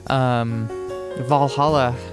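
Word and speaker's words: VA-11 Hall-A: But you heard Welcome to Valhalla, that is spelled VA-11 space capital HALL dash capital A. Valhalla, because you got to make your bar name sound really fancy and cool, I guess.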